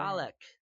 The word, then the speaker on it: Dalek: Dalek. I said it correctly that time. – You did.